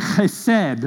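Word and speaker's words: chesed (חסד): When you're pronouncing an H in Hebrew, you kind of got to start it back here in the back of your throat and say chesed, okay?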